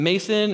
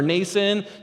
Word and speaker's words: Mnason: It's M-N-A-S-O-N, Mnason. How do you, you know what I mean? Is it Mason or Mnason? No, it's Mnason, all right?